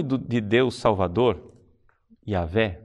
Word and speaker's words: Yahvé: Provavelmente a pronúncia do nome seria Yahvé.